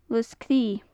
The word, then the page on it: lus-cre: gaelic